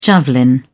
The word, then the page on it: javelin: British English